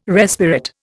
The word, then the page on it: respirate: Indian